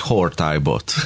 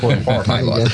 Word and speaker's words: Hortybot: És akkor majd így fogjuk kiejteni, hogy Hortybot. – Hortybot.